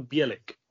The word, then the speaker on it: Bielik: Oh, is that how you pronounce it? – Yeah, Christian Bielik, yeah. – Bielik. I didn't know if that was Bielik or Bielik. That's me. – Bielik. Yeah, there's stress of B on the first syllable, yeah.